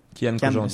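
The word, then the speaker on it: Kyan Khojandi: Du moins, tous les juges humoristes, Eric euh, Antoine, euh, Sugar Sammy, euh, Kiev Conjandi, je le prononce bien son nom? Kian. Kian Conjandi.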